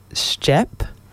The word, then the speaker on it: Stjep: and I think it's pronounced Stjep, STJEP, and they've got a new release and it's called Storied.